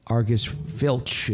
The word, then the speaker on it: Argus Filch: Argus Filch is out collecting scraps.